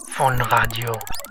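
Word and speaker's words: Phaune Radio: Phone radio. I mean, phone radio. It's spelled P-H-A-U-N-E. But it's pronounced phone. – And it's radio. – Phone radio. I mean, phone radio. It's spelled P-H-A-U-N-E.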